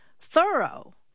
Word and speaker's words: Thoreau: All this time, I thought his name was pronounced Thoreau, but no. – How is it pronounced then? – Thoreau.